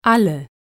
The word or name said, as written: alle